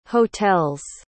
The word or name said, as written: hotels